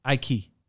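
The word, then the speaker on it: İlkay: I call him, I don't know what you call, in my language, we pronounce it Ike. – Ike.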